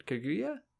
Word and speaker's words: Kaguya: Somebody shows up and they say, like, it's time to use you for the Kaguya clan. Kaguya? Sure. – Kaguya. – Kaguya. I was putting the emphasis in the wrong syllable, as I typically do.